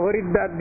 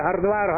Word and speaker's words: হরিদ্বার: সেই স্বর্গে আরোহণ করবার যে রাস্তাটা, সেই রাস্তাটা হলো হরিদ্বার দিয়ে আরম্ভ। হরিদ্বার থেকে আরম্ভ সেই রাস্তা উত্তরাখণ্ডের মেন গেট হচ্ছে হরিদ্বার। যদ্যপি হরিদ্বার আজকাল উচ্চারণ ভেদে হরদ্বার হরদ্বার হয়ে যাচ্ছে, কিন্তু হরদ্বার নহে, হরিদ্বার।